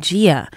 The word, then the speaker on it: Gaia: That was Gaia's Garden. I pronounce it Gaia, but I heard her saying Gia.